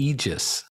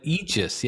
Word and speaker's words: aegis: I have always pronounced that incorrectly as well, the aegis. But aegis, yeah, it makes complete sense that it's aegis.